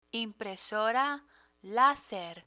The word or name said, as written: impresora laser